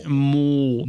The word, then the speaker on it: Maul: Maul. – That's how you say it. – Yeah. I know. It's in episode 1. It's canon.